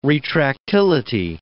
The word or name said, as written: retractility